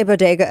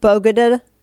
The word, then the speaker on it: bodega: You got, how does she say bodega again? I cannot say it wrong. It's like bogada. Bogota? Yeah. Is that what she says? – Bodega. – Bodega.